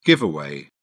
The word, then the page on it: giveaway: British English